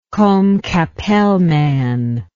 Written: combe capelle man

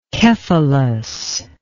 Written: Kephalos

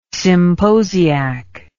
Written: symposiac

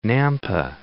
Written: Nampa

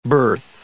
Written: berth

